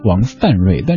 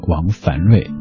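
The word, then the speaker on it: 王梵瑞: 在改名之后应该念王范瑞，但这么念挺奇怪的，所以一直还是坚持念王凡瑞。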